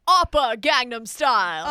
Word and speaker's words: Oppa Gangnam Style: Mother Gundam style. – Excuse me, Kevin Spacey's accent here. That's not, it's pronounced Oppa Gangnam Style.